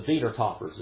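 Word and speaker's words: Wiedertäufer: The Wiedertaufers is how you say it in German.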